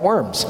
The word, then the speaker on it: Worms: So Charles V called a diet, coming together in the German city of Worms, W-U-R-M-Z usually, but it's pronounced like Worms.